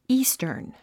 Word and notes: eastern: íːstərn; イースタン

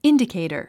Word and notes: indicator: índikèitər; インディケィタァ

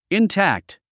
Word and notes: intact: intǽkt; インタァクト

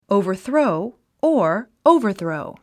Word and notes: overthrow: òuvə r θróu; オウバァスロウ

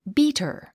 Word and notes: beater: bíːtər; ビータァ

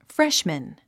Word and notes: freshman: fréʃmən; フレッシュマン

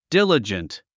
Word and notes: diligent: dílədʒənt; ディラジェント

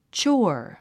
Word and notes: chore: tʃɔ́ː r; チョォワァ

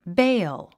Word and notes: bail: béil; ベェョ

